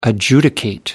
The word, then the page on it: adjudicate: American English